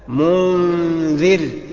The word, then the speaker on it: منذر: تبقى منذر، منذر، ما اقولش مو، لا منذر، مرققة الغنة، ايه مرققة؟ لان بعد منها حرف استفال وهو الزال، حرف مرقق، تبقى مرققة اخرى. يبقى النطق الصحيح منذر، منذر، مرققة غنة بمقدار حركتين اتنين.